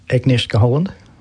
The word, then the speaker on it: Agnieszka Holland: One that's been a winner at the Venice Film Festival is Green Border, and that involves the veteran Polish director Agnieszka Holland.